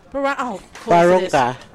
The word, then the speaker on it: Barunga: So you need to be from Bulman, Biswick, and of course, tell me how do I pronounce this last one, please? – Barunga. – Barunga. There we go. We'll sound it correctly.